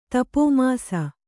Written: ತಪೋಮಾಸ

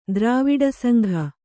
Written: ದ್ರಾವಿಡಸಂಘ

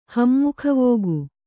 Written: ಹಮ್ಮುಖವೋಗು

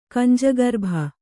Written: ಕಂಜಗರ್ಭ

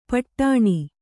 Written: ಪೀಡನ

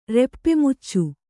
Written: ರೆಪ್ಪೆಮುಚ್ಚು

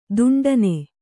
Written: ದಿಂಟೆನಾ

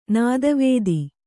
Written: ನೆತ್ತಿವತ್ತು

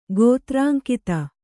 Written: ಗೋತ್ರಾಂಕಿತ

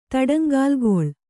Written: ತಡಂಗಾಲ್ಗೊಳ್

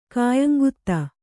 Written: ಖಾಯಂಗುತ್ತಾ

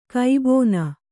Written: ಕಬ್ಬುನ

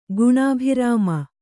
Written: ಗುಣಾಭಿರಾಮೆ

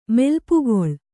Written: ಮೆಲ್ಪುಗೊಳ್

